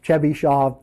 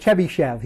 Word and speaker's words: Chebyshev: And the spelling is unusual, but I agree with Sturzacher that this is the correct transliteration of the name, because the name in Russian is pronounced Chebyshov, because after the sha consonant, you can't have an a. So you'll see this guy called Chebyshev. He's got a bunch of polynomials that he's famous for also. But his name should be pronounced Chebyshov, and we'll spell it that way.